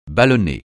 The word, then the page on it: ballonné: francais